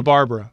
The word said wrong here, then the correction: Barbera: you can do the tags and get the pronunciation, Barbera. – Barbera. Barbera. I was like Hanna-Barbera.